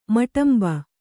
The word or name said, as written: ಮಟಂಬ